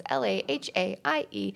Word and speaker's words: Lahaie: If you follow me at Shannon Lahaie, it's just how my name is spelled, but my last name has so many vowels, so it's Lahaie.